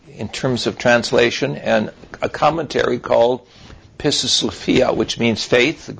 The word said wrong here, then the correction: Pistis: Because it's an unusual P-I-S-T-I-S, Pistis, Sophia, S-O-P-H-I-A.